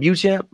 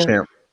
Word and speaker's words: Beauchamp: I don't know how much I expect for him seriously in like crunch time, but uh MarJon Buchamp, I hope I'm pronouncing his name right. – Buchamp. Beauchamp.